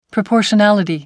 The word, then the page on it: proportionality: American English